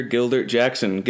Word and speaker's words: Gildart Jackson: Today I'm joined by actor and fireside reading narrator Gildart Jackson.